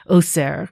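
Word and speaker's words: oser (עושר): But the funny thing is that there's a homophone for oser, because oser is spelled with an ayin at the beginning. But then there's oser with an aleph at the beginning, and it's pronounced the same way, but it means happiness.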